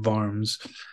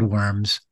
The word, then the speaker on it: Worms: Well, so do the locals say Worms or Varms? – They said Worms. Yeah. Maybe, you know, 20 years prior, there were a few people still saying Varm.